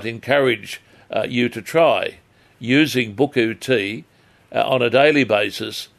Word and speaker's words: buchu: known as buku, and I'll spell it for you, B-U-C-H-U.